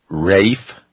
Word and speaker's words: reification: They use the word reification or the word RAIF, if you want to pronounce it that way, R-E-I-F, the first syllable in reification.